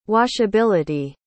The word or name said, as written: washability